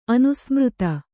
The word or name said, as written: ಅನುಸ್ಮೃತ